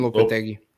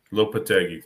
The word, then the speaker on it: Lopetegui: Julian Lopetegui. – Lopetegui, thank you.